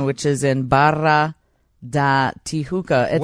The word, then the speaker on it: Tijuca: Tijuca.